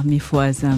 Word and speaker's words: Mifohaza: Is that the reason you wrote the and sang the song on your album, the Mifohaza? Did I pronounce that correctly? – Yeah, Mifohaza, Mifohaza, which means wake up.